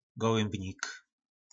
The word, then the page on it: golymbnik: po nasimu